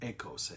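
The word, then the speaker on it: echos (ἦχος): It's the word echos, or it's actually pronounced echos, I think. E-C-H-O-S, which we think of as echo, okay? But in Greek, the E is an A sound, echos, okay?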